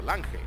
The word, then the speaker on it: Lange: Lange se escribe con G, su apellido, Lange.